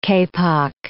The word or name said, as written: kapok